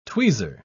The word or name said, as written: tweezer